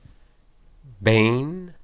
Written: ben

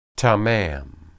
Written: tamam

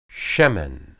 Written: shemen

